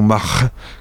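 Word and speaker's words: march'h: Une troisième possibilité, qui, elle, serait plus fautive d'après les linguistes, c'est Mar, qui se prononce en breton Mar, qui est M-A-R-C apostrophe H,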